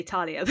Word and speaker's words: Thalia: I say Thalia. Sorry, so you're going to get two different pronunciations of the name.